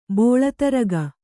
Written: ಬೋಳತರಗ

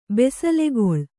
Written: ಬಿಸಿಲುಗೋಲು

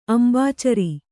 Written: ಅಂಬುಚರ

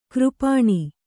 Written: ಕರಪಾಣಿ

